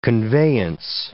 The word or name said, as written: conveyance